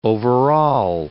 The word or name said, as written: overall